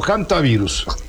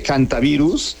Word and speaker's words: hantavirus: ¿Hantavirus o hantavirus? – Se dice hantavirus, aunque se escribe con H, por la cuestión anglosajona que hace que la H suene como J. Pero es hantavirus.